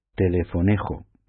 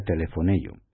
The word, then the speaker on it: telefonejo: pronunciado ello, aunque se escribe con J al final, ¿no? En castellano lo pronunciaríamos telefonejo. Bueno, pues se pronuncia telefonello.